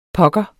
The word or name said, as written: pokker